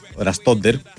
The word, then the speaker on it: Ραστόντερ: Δεν τον ήρθε και ο Ραστόντερ. Έτσι τονίζεται, έτσι είναι το σωστό.